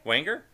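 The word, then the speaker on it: Weinger: And that came out in 1992, starring Scott Winger. Is that how you say his name? – Wanger.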